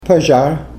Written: pazhar

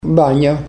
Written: banya

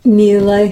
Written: milay

